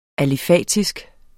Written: alifatisk

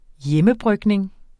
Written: hjemmebrygning

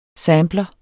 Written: sampler

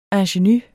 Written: ingenue

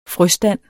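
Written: FRØSTANDE

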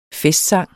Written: festsang

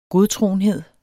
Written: godtroenhed

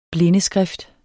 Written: blindeskrift

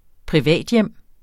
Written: privathjem

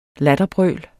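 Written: latterbrøl

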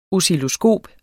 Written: oscilloskop

